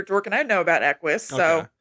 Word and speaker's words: Equus: Equus? – Yes.